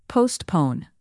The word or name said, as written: postpone